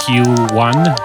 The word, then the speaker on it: QEONE: In July of last year, she founded her own label called Q1. It's spelled Q-E-O-N-E.